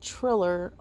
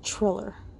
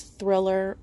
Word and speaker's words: Triller: Triller yeah, I think I'm pronouncing it right. I thought it was Thriller, regardless. There's no H.